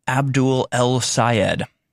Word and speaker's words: Abdul El-Sayed: Michigan Senate candidate Abdul Al-Sayed said you cannot... If you can't pronounce his name, it's easy to pronounce. Abdul El-Sayed.